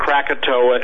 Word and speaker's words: Krakatoa: And Krakatoa is spelled K-R-A-K-A-T-O-A, and not Krakatoa in the dark, okay?